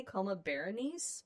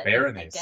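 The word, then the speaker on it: Coma Berenices: You're looking at me like I said it wrong. – Oh, well, I don't know how you really pronounce it. I say Coma Berenice, but Berenice. I guess it could be Bernice.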